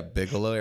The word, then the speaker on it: Bigelow: I'm going to really butcher this, but Bigelow? – Yeah, Bigelow Aeronautics.